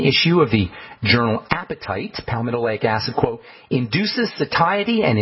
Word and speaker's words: palmitoleic: That's one of the neatest benefits of palmitoleic acid. Palmitoleic is spelled P-A-L-P-A-L-M-O-L-E-I-C, palmitoleic acid.